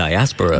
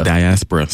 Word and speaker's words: Diaspora: The Diaspora. – Diaspora, sorry.